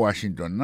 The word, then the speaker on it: Washington: Si n'a pas mettre accent sur accent encore, on va voir que mot Washington là, accent tombé sur premier syllabe là.